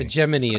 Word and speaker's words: hegemony: to the European hegemony? Hegemony? How do I say that? Hegemony? – The way they did in this was hegemony. – Hegemony. – Hegemony is right.